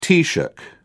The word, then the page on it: taoiseach: British English